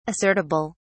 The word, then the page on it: assertible: British English